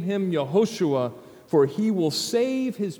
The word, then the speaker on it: Yehoshua: It's Joshua, or more accurately, Yehoshua. And can you hear that first syllable? Yeah, Hoshua. Yah, the name of God.